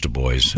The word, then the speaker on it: Du Bois: Du Bois. – No, it's pronounced Du Bois.